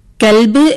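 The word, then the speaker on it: കൊൽബ് (قلب): അതുപോലെ ക എന്ന അക്ഷരത്തിന് 2 ഉച്ചാരണങ്ങൾ വരുന്നു. ഒന്ന് ക, ഒന്ന് ക. കൽബ് എന്ന് പറയുമ്പോൾ നായ എന്നർത്ഥം വരുന്നു. എന്നാൽ കൊൽബ് എന്ന് വരുമ്പോൾ അതിന് ഹൃദയം എന്നാണ് അർത്ഥം വരുന്നത്. അപ്പോൾ കായും കായും ശ്രദ്ധിച്ചിട്ടില്ലെങ്കിൽ അർത്ഥവ്യത്യാസം നമുക്ക് കാണാൻ സാധിക്കും.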